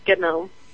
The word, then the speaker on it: GNOME: And there was a question here in the chat room that was asking if the G in GNOME is silent, and I think from the way we're all pronouncing it, it's not. – It's not silent. That's probably the most common question I get. It's GNOME, like GNU, like the GNU project.